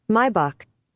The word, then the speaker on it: Maybach: By the way, funny with the pronunciation. Some people were like, what is he saying? Maybach. You got to just see what the Germans say. Go ahead and play it, Will. – Maybach. – Bingo.